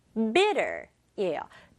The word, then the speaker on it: bitter: Bitter, bitter예요. Bitter인데 빠르게 발음하면 bitter. 이렇게 T 소리 약하게 내요.